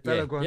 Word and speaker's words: Balogun: And if Balogun, am I pronouncing his name right? – Balogun. Yeah.